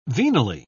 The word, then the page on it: venally: British English